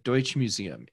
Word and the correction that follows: Deutsches Museum: in Munich. – Mm-hmm. Deutsches Museum, yeah.